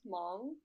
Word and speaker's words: Hmong: Yeah, so what Harsha is getting at is my ethnicity is Hmong, which is spelled H-M-O-N-G, so there's like a silent H in it.